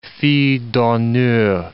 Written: fille d'honneur

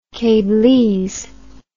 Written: cablese

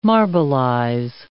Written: marbleize

